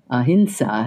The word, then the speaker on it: ahimsa: One of their primary teachings is ahimsa. Ahimsa, as I have learned, is the correct pronunciation for that word.